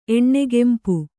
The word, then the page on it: ಎಣ್ಣೆಗೆಂಪು: kannada